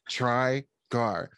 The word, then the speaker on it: Trigar: Just it's like it literally is pronounced how it looks. – Yeah. – Trigar, which I don't know the origin of that name.